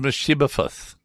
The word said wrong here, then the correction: Mephibosheth: Have you written it down? Yeah, have a look. You won't be able to read my writing. Mephibosheth. – Mephibosheth.